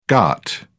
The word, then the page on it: ghat: British English